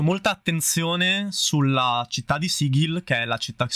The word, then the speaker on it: Sigil: è Sigil, Sigil, sì, tecnicamente è Sigil con la G dura.